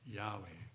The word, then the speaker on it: Yahweh: Yahweh. Again, I'll spell it for you. Y-A-H-W-E-H.